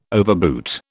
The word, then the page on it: overboot: British English